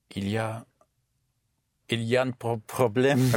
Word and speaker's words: il y a: An expression that is used a lot in French is il y a. Il y a. Spelled I-L-Y and A. Il-I-A, but pronounced il-ya.